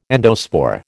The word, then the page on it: endospore: American English